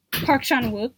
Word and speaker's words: Park Chan-wook: I love Shanwen. I can never say his name. – Park Chan-wook.